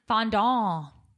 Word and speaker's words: fondant: fondant. What? Fondant. – A what? That's how you say it.